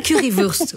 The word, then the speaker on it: Currywurst: C'est Currywurst. Oui, c'est ça, c'est vrai. C'est Wurst. Oui, c'est, voilà.